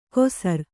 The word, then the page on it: ಕೊಸರ್: kosar